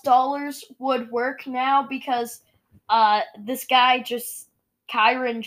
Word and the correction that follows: Charon: just, Chiron, however you pronounce his name. He just wants... – No, Charon. – Yeah, Charon. He just wants money.